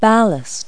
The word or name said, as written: ballast